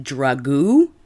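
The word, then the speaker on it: Dragoo: Wendy Gillenwater and Greg, I'm going to pronounce this name Drago. It's D-R-A-G-O-O. Drago. – That's like, okay. Sounds like something you put on spaghetti.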